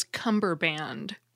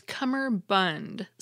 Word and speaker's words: cummerbund: First, I always thought it was cummerband, but it's cummerbund, C-U-M-M-E-R, bund, B-U-N-D, cummerbund.